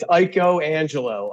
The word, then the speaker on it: Iaconangelo: You botched it up just like everybody else does, but that's okay. – Say it, Boomer. – It's Iaconangelo.